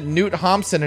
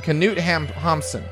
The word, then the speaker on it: Knut Hamsun: It's I always pronounce it Knut Hamsun, and he wrote a book I liked called Growth of the Soil, and I'm right. – Ah. – Knut Hamsun.